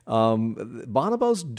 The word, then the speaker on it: bonobo: And thank you for saying it correctly. I'm sorry. – Yeah. Oh, no. It's tomato, tomato. You could say okay. Bonobo or bonobo.